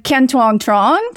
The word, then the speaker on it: Kien Tuong Truong: And we have Kien Tuong Truong. Did I say that right? – Yeah, that works.